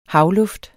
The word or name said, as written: havluft